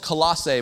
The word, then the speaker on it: Colossae: Um, and yes, the, the city is called Colossae. My wife, my wife, I got off stage and she said, Are you sure that's how you say it? Yes, I am sure.